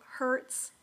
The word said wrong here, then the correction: Heuertz: Hertz. – Heuertz. – Heuertz. Thank mm-hmm.